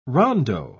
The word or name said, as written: rondo